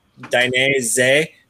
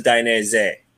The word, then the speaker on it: Dainese: So the way I kind of broke it down for it is Dainese. So Z E H at the end. So Dainese. – Z-E-H. And knowledge has just been dropped on us. – Yeah. Easiest way to pronounce it.